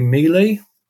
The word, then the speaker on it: Mele: Mealy.